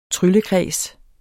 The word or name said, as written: tryllekreds